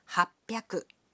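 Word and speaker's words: はっぴゃく: 300 is pronounced sanbyaku. 600 becomes ropyaku. And 800 is pronounced hapyaku.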